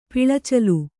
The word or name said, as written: ಪಿಳಚಲು